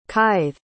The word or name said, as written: kithe